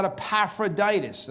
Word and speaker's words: Epaphroditus: Then he talks about Epaphroditus.